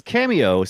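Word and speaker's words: Kameo: It is Cameo, spelled with a K.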